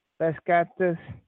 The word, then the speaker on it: Beşiktaş: B-E-S-I-K-T-A-S. Beşiktaş.